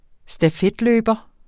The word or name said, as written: stafetløber